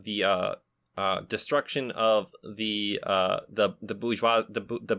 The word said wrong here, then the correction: bourgeoisie: bourgeois the, how do you say that again, Lady Lees? – The bourgeoisie.